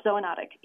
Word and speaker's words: zoonotic: Zoonotic, is that how I pronounce it? – Correct, zoonotic, yes.